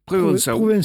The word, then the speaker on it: provençal: Provençau. – Provençal, puisqu'en provençal, on vocalise le L final qui devient AU, AU.